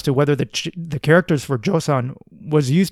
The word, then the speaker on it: Joseon: The modern-day Korean pronunciation is Joseon, and thus we have the first name for Korea ever.